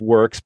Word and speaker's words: Whirks: First of all, Works is spelled W-H-I-R-K-S. So it's pronounced Works, but it's spelled not as you would typically think of it.